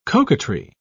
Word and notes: coquetry: British English

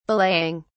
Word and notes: belaying: British English

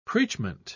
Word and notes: preachment: British English

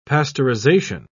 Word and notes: pasteurisation: British English

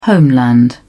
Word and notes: homeland: British English

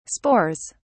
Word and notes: spores: British English

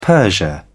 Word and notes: persia: British English

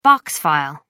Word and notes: box file: American English

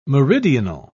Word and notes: meridional: British English

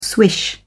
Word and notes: swish: British English

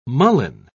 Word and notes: mullein: British English

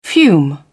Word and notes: fume: British English

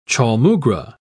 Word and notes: chaulmoogra: British English

